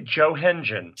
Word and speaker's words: Johengen: Is it Johangen? – It's actually Johengen.